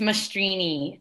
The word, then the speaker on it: Mastrine: No, it's Mastrini. It's Italian.